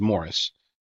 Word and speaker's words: Maurice: Another David Rockefeller protege was Canadian millionaire Maurice. It's pronounced Morris. Spelled Maurice, but it's Morris.